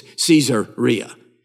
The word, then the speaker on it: Caesarea: But if I say it the way it's spelled, you'll understand. It's Caesarea.